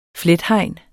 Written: flethegn

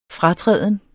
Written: fratræden

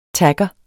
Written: tagger

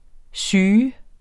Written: syg

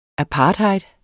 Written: apartheid

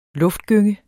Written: luftgynge

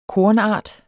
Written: KORNARTER

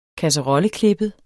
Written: kasserolleklippet

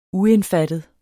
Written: uindfattet

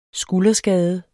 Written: skulderskade

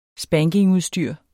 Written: spankingudstyr

